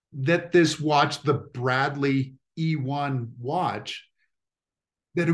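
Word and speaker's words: Eone: So the Eon watch, is that how you pronounce it? Eon? – E1. – E1. Okay, that makes more sense.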